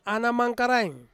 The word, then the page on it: ana mangkaraing: Ansus